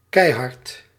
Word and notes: brazen: /'bɹeɪz(ə)n/

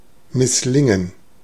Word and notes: misslingen: /mɪsˈlɪŋən/

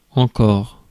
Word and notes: yet: /jɛt/; [jɛt]

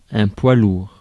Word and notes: poids lourd: France (Île-de-France); /pwa luʁ/

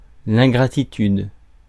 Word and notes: ingratitude: /ɛ̃.ɡʁa.ti.tyd/; France

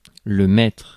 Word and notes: chief: /tʃiːf/

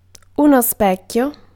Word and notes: miroir: France (Paris); [ɛ̃ mi.ʁwɑʁ]

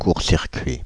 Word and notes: short: English

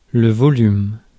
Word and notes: volume: /ˈvɑl.jum/; American English

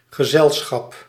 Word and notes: company: /ˈkʌmpəni/

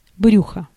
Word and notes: belly: /ˈbɛl.i/; [ˈbɛl.i]

